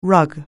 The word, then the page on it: rug: anglais